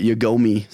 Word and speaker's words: Yogomi: You guys know where to find Yogami. You know where to find Bobo. You know where to find me. I got to say it right. I'm sorry. Yogomi. Sorry. You know where to find Yogomi. I'm going to get it.